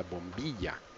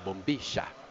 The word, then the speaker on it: bombilla: And then you pour hot water into it, and you have what is known as a bombilla. These guys say bombilla because they don't know how to pronounce a double L sound in Argentina. – Come on, Colombian, you can say it.